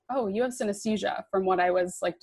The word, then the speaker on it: synesthesia: But just for clarification's sake, because I didn't know what it was, how do you say it? Synesthesia? – Yeah, synesthesia.